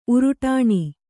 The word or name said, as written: ಉರುಟಾಣಿ